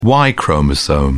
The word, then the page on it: y chromosome: British English